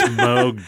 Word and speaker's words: Moog: I can't think of his first name, but he said Moog. And I'm like, okay, that's the man. So I should listen to him, I think. Because he's not just some pretentious douche at the bar. It's Moog, dude.